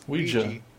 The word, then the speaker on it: Ouija: It's Ouija. – It's... French for yes and German for yes.